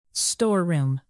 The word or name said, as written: storeroom